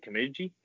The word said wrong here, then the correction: Kijimi: Kijimi. – Kijimi.